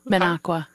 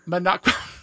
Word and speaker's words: Minocqua: And the mine? Minnekauza? – Minocqua. – Minocqua. I am so not into my, I have to get my Wisconsin geography back. Pronounce it again.